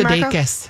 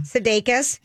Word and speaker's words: Sudeikis: How do you say his name, Sudeikis. – There you go. – Sudeikis.